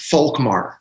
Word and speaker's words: Volkmar: So my brother, his first name, now it's a German name, but it's pronounced Volkmar.